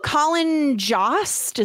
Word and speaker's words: Colin Jost: Oh, Colin Jost, is that how you pronounce it? – Yeah.